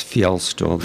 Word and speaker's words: Fjelstul: It's Fjelstul. The J is silent. It's a Norwegian name.